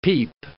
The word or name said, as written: peep